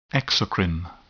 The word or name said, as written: exocrine